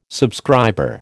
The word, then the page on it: subscriber: American English